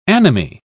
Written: anomie

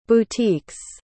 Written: boutiques

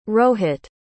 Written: rohit

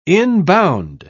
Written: inbound

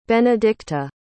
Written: benedicta

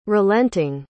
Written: relenting

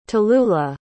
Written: talulah

